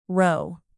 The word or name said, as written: roe